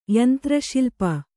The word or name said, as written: ಯಂತ್ರಶಿಲ್ಪಿ